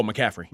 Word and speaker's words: McCaffrey: You don't think Shanahan spent the whole offseason drawing up McCarthy plays Mc, or uh, McCart? How do you say his name? The running back. – Oh, McCaffrey.